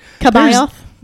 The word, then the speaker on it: C'baoth: It is spelled C apostrophe. – Oh no. B A O T H. – Kabayoth.